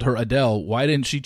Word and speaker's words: Adele: And she says the correct pronunciation of her name is Adele, not Adele.